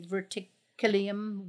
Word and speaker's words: verticillium: Which means it's resistant to how do you say verticillium wilt? – Verticillium.